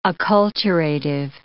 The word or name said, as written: acculturative